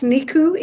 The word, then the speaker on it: Nicu: And this is from, I think it's pronounced Nicu. It's N-I-C-U.